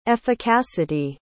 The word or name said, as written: efficacity